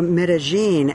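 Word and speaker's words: Medellín: Today, Medellin, or Medellin, as it's pronounced here, is peaceful.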